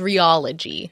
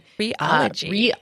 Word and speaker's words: rheology: So I recently learned, it's called rheology. – Rheology. – Rheology, but it's spelled R-H-E-O-L-O-G-Y. And this is like this burgeoning scientific field that's studying the science of texture.